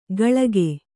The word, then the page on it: ಕಳಕೆ: kaḷake